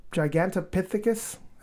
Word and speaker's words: Gigantopithecus: Gigantopithecus? Is that how you say it? – Looks like it.